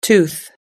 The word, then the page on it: tooth: American English